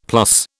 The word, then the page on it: plus: British English